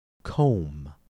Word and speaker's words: comb: Comb. Combine. Notice how the B's in each of these words are pronounced differently. In the word comb, C-O-M-B, the B is silent.